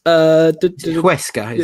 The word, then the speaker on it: Huesca: Huesca, is it? – Yes, that's it.